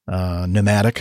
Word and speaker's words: pneumatic: There, all these words are words that start with a certain letter, but they don't really sound like that letter at all, or the letter is silent. Pneumatic for P.